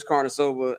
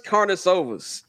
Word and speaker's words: Karnisovas: Karnisovas.